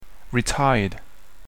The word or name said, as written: retired